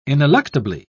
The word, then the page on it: ineluctably: British English